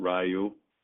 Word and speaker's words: Ryu: You got Ryu.